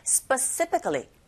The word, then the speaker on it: specifically: 나눠서 발음한다고 생각하실 때 강세는 뒤에 간다는 거 specifically specifically 이렇게 하시면 돼요.